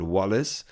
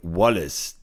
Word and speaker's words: Wallace: Außer der Spieler sagt übrigens mal, dass ich seinen Namen falsch ausspreche, so bleibe ich bei Wallace, weil ich habe mal den Dolmetscher von ihm gehört in einem Interview und da hat er ihn mit Wallace angesprochen